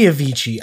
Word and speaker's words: Avicii: It's gotta be Avicii. I've always pronounced it fucking Avicii. It's two I's. It's E. It's an E sound.